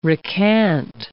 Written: recant

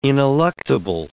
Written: ineluctable

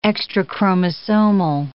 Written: extrachromosomal

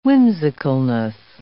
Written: whimsicalness

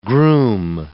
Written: groom

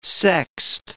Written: sexed

